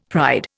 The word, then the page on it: pride: Indian